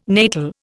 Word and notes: natal: Indian

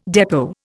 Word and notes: depot: Indian

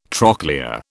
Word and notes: trochlea: British English

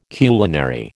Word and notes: culinary: American English

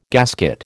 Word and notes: gasket: American English

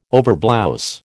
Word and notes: overblouse: American English

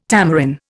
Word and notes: tamarin: Indian

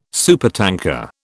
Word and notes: supertanker: British English

